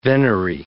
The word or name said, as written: venery